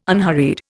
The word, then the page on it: unhurried: Indian